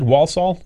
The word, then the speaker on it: Walsall: Walsall.